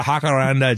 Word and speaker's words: jacaranda: On the jacarando, is that how you say it? – Well, you can call it a jacaranda, jacaranda. You call it whatever you like. It doesn't know its name. In Spanish, it's a jacaranda.